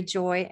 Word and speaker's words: Joye: It's Mary Joye, and Joy is spelled with an E on the end, J O Y E.